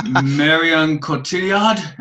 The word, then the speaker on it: Cotillard: Marion Cotillard? Am I saying it right? Okay. – That is the name. That's just saying it right.